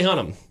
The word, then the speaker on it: Hunnam: in Charlie Hunnam.